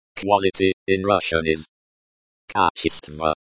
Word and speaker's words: Качество: Качество. Качество. This is the noun, quality. The stress is on the second syllable, ч.